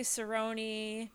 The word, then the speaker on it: Cerone: Some people say Cerone, but not many.